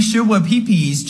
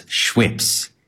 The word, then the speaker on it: Schweppes: Literally don't know how to pronounce this word, but PepsiCo just officially recalled their sugar-free Shua Peepees ginger ale. – Shua Peepees is how he pronounces Schweppes. Shua Peepees.